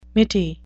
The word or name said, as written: mitty